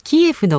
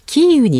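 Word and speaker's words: キ ー ウ (Київ): は我々を滅ぼすために戦い続ける国の代表の言葉を信じる根拠がないと述べました日本政府は昨日ウクライナの首都のキエフの呼称はロシア語に由来するとしてウクライナ語の発音に近いキーウに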